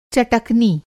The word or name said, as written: चटकनी